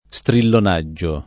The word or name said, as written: strillonaggio